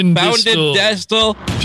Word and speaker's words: Budenstitzel: Budenstitzel. Just like it's spelled.